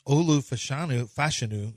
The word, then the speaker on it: Olu Fashanu: Olu Fashanu is how it's pronounced.